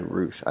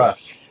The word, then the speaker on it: Rusch: but is it Rush or Roosh? I just pronounced it horribly. – Rush. – Yeah.